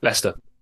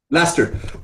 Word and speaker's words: Leicester: Leicester. – Leicester.